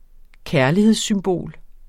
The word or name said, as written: kærlighedssymbol